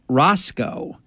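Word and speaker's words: Racicot: I think his first name was Mark, but I do know his last name, it was pronounced Roscoe, which was very strange.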